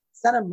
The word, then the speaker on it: Senum: Senum, like Senum to Sacramento.